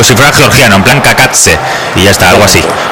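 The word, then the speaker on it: Cacace: La caída de Liberato Kakatse, he tenido que buscar cómo se pronunciaba este futbolista para no cometer errores. Y según la IA es Kakatse.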